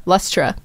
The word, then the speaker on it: Lustra: But this is Scotty Doesn't Know by Lustua. How do you say that? Lustua. – Lustra? – Yeah, Lustra.